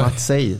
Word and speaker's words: Maciek: Visste du att eh, Maciek uttala så. – Nej. – Matsej står det.